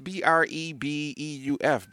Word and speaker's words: Brebeuf: Hey, if you guys know how to pronounce his school in Indianapolis, it's Brefeu Jesuit. I'm gonna go with that. – Brefeu. It's Brebeuf, Bryce.